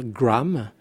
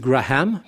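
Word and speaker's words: Graeme: Et donc, si on essaye de prononcer correctement, nous, Français, c'est pas toujours facile, on doit essayer de ne pas dire Graham, ni Graham, puisqu'il n'y a pas d'H, mais Graham.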